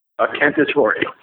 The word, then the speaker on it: Cannatore: Cannatore. – And I assume that's not Scottish. – Nope, that's Italian. Just like the Italian Italian.